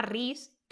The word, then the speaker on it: Rhys: se llama Riz, Riz como el de Acotar, o sea, no en pronunciación es igual, también se escribe igual, R-H-Y-S.